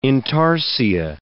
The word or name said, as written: intarsia